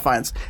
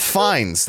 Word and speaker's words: Fiennes: That's how it's spelled, but he pronounces it Ralph Fiennes. – We'll see. Anyway, no, like he in interviews. – Hi, I'm Ralph Fiennes.